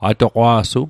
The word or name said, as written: atoqwa'su